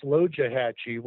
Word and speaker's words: Loxahatchee: I do want to mention that Loxahatchee really should be pronounced Lojahatchee, which means Turtle River.